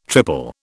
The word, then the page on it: triple: British English